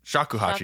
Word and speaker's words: shakuhachi: The shikuhachi, or did I say it right? Shakuhachi, excuse me.